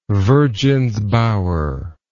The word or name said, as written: virgins bower